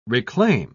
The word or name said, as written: reclaim